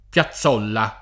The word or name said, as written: Piazzolla